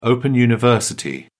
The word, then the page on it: open-university: British English